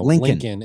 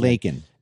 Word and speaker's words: Laken: You know, Lincoln instead of Lakin.